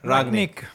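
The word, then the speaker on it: Rangnick: Ragnic. – No, I don't think there's a ch sound. He's German. – Okay, Ragnic.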